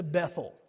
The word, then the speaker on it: Bethel: He went up from there to Bethel. Now let's stop there for just a second. Bethel, if you're going to say it the right way, is actually Beth-El. It comes in two syllables.